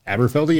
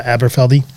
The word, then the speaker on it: Aberfeldy: Um, this 12 year old from uh, Aberfeldy is that how you pronounce it? – Aberfeldy, yeah. – Uh, Aberfeldy.